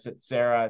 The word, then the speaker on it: Tsetserra: Again, we're active in Texas buying businesses, but you can get a hold of us at Setsera. It's spelled T-S-E-T-S-E-R-R-A.